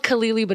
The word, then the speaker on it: Khalili: it's spelled Khalili, but it's actually Khalili.